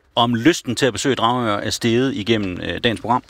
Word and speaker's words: Dragør: De skriver, at vi udtaler Dragør forkert. – Dragør? – Dragør. – Dragør. – Men jeg mener altså, det er Dragør.